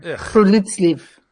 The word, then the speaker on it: friluftsliv: It's spelled F-R-I-L-U-F-T-S-L-I-V, but it's pronounced friluftsliv.